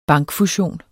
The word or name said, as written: bankfusion